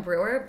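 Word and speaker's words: Bruewer: My name is Autumn Brewer,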